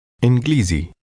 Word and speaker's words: إنجليزي: إنجليزي إنجليزي زي Lizzy.